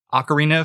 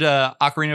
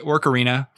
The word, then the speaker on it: Ocarina: But no, I loved Ocarina of Time. I pronounced it Orcarina for a good long portion of my career. – Orc as in like J.R.R. Tolkien orcs? – I stand by my pronunciations.